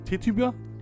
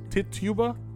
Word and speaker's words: Tituba: With Sarah Good, Sarah Osborne, and I think her name's pronounced Tituba. – Tituba. – I think it's right. Tituba.